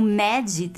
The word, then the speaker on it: mad: A pronúncia dessas palavras em inglês é start, print, né, mad. Deu pra perceber como ficam leves, né, o T e o D no fim dessas palavras. Uhum.